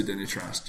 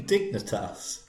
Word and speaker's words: Dignitas: How do you euthanise yourself when you go to Dignitas? Do you walk into the... You're right. – Don't you mean Dignitas? – Well, however it's pronounced. – Pronounce a G as an N.